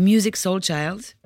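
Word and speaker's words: Musiq Soulchild: Une chanson qui s'appelle Womanopoly de Music Soul Child. Music, ça s'écrit avec un Q à la fin.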